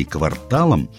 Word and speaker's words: квартал: В специальной терминологии кварталом называют часть засаженной земли прямоугольной формы. Но независимо от смысла, говорить мы должны «квартал» с ударением на вторую «а».